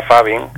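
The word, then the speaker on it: phubbing: No sé si dice Fubin o Fabin. ¿Qué es eso del Fubin o Fabin? – Bueno, se pronunciaría Fabin, que es un término acuñado por los anglosajones, que es la conjunción de la palabra teléfono y ignorar en inglés, ¿no?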